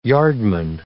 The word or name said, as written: yardman